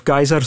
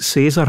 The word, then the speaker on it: Caesar: Wat gebeurt dan omdat Caesar of Keizer, zoals je wil, net zo'n bekende figuur is geworden en zijn naam in zoveel talen wordt gebruikt? Wel, kan je evengoed die latere uitspraak Caesar hanteren, zoals je van mij ook mag variëren tussen Cicero en Cicero.